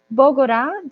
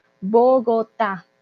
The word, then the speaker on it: Bogotá: Entonces no es Bogorá, también eso es muy importante, esa T sí se pronuncia, y la tilde en la A, por favor, Bogotá, ¿vale?